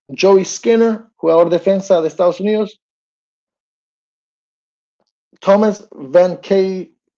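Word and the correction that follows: Vancaeyezeele: es que lo digo mal, Van Kaisel, de Guyana francesa.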